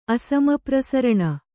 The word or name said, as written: ಅಸಮಪ್ರಸರಣ